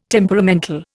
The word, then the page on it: temperamental: Indian